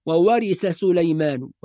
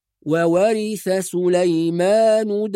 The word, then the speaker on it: وورث سليمان: مهم جدا الانتباه إلى حرف الثاء، فبعض الناس يقول وورث سليمان، يحول الثاء إلى سين، كأنه ينطق سين وبعدها سين، وورث سليمان، وهذا خطأ، وإنما وورث بالثاء، وكلمة سليمان بالسين، فنفصل بين الثاء والسين، هذا حرف وهذا حرف، فنقول وورث سليمان داود.